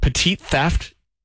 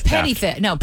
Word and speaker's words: petty theft: And do you say, is it petite theft? Do you say it like you're French? Oh, petite or petit. – It's petty theft. Petty theft.